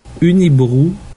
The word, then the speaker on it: Unibroue: Yeah, I mean, Okay, the correct pronunciation is, maybe we should copy, put in the piece of the wave file right here. The correct pronunciation of this is Unibroue. – But we're just going to say Unibroue because it's so much easier for us. – We're not French speakers.